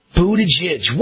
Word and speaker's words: Buttigieg: How do you pronounce his name? Is it Pete Buttigieg? Something like that. It's Buttigieg. Whatever.